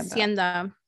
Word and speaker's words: Hacienda: Or is that how you say it? Hacienda or Hacienda? – Hacienda. Hacienda. Yes.